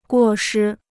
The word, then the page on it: 过失: guò shī